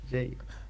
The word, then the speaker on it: Jay: Jay. – ¿Cómo se escribe? Ah, sí. – Como J en inglés. – Jay.